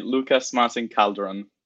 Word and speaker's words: Lucas Martín Calderón: I didn't want to make the mistake of taking your surname wrong, but tell me your full name once again for the about 50th time. – All right. So once again, it would be Lucas Martín Calderón.